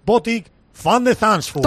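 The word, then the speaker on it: Botic van de Zandschulp: Responde al nombre y apellidos de Botic Van de Zandvoort.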